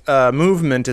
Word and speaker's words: MVMT: One brief note, Movement is spelled MVMT.